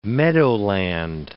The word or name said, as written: meadowland